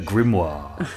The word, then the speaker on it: grimoire: What, do you put the pronunciation on grim or moir? – I think grim, isn't it? – A grimoire. – Grimoire. – Not a grimoire. – Otherwise it sounds like a soiree.